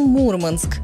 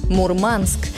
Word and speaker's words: Мурманск: В обычной речи мы говорим «Мурманск» с ударением на первый слог, и это правильно. Но в морской среде иногда можно услышать другой вариант – «Мурманск». Это уже не про словари, а про профессиональную речь.